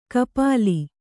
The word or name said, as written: ಖಪಲಿ